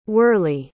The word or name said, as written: whirley